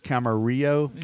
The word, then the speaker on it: Camarillo: And can I pronounce this? Camarillo, California? – You got it.